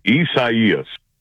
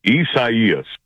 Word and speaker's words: Isaias: Isaias. – One more time, please. – Isaias. – All right. Thank you, Mark. Appreciate that. Gina, I want you to know I went to my desk. – Isaias.